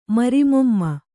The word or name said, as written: ಮರಿಮೊಮ್ಮ